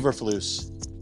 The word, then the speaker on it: Eberflus: I'm not saying, I don't know how even to pronounce his name, Matt Eflurbis. I don't know how to pronounce his name. – Oh, Eberflus.